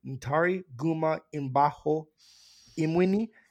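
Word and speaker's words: Ntare Guma Mbaho Mwine: The lead actor in this film, Natari Gumba, well, I want to get his name right. Ntari Guma Imbaho Imwini.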